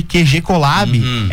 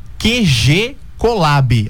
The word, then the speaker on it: QG Collab: Então, falando de QG Collab, é só procurar no Instagram ou no Facebook, @QG Collab, assim como se fala mesmo, Perfeito. – Né? QG Collab. QG é de quartel general e Collab é de colaborativo, colaboração, né?